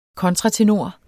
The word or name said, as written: kontratenor